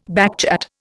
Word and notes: backchat: Indian

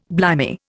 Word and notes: blimey: Indian

